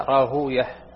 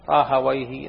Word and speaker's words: راهويه: وقال إسحاق بن راهوية رحمه الله تعالى: يقال إسحاق بن راهوية وإسحاق بن راهوي، والفائدة أهل الحديث ينطقون هكذا: راهوية، نفطوية، سيبوية، وأهل اللغة راهويه، نفطويه، سيبويه.